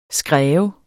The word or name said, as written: skræv